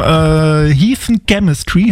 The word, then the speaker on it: Heathen Chemistry: Wir machen heute Musik aus dem Jahr 2002 und da gab es das Album Heathen Chemistry.